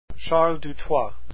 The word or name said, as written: Dutoit